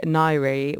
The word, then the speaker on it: Ngaire: This is by Nairi, which is spelled N-G-A-I-R-E, featuring Napalm.